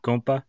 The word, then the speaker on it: Compa: Saying that right, Compa? – Yeah, it's supposed to be like compadre, but people just say compa like it's slang.